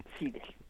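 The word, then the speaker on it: Slidell: que hace es mandar a John Seidel, se pronuncia, Seidel,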